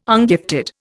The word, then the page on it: ungifted: Indian